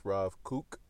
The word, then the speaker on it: Kook: This is a Torah that I wanted to share from Rav Cook's Torah, uh, or sometimes pronounced Rav Kook, and this is from Parsha Chayei Sarah.